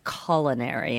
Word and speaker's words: culinary: Well, in America, we say culinary and instead of culinary. – Okay. – So he said, it's culinary. And I said, well, I think it's both, but it's fine.